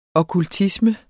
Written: okkultisme

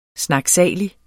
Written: snakkesalige

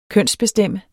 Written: kønsbestemt

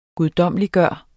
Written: guddommeliggøre

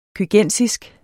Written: køgensisk

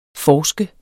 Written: forsker

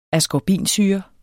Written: askorbinsyre

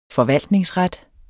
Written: forvaltningsret

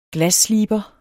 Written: GLASSLIBER

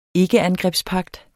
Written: ikkeangrebspagt

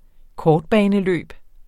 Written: kortbaneløb

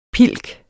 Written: pilken

